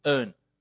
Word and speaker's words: Hearn: Just behind you guys, Earn Street, Earn, H, Earn, how do you spell it? H-E-A-R-N, Earn Street,